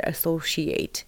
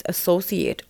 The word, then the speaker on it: associate: By the way, I checked the word associate. It turns out that it can be pronounced both ways associate or associate.